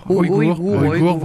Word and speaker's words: Ouïghours: Les Ouïghours. – Voilà. Comment vous prononcez ça? – Ouïghours.